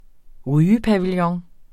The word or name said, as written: rygepavillon